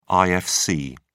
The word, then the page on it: ifc: British English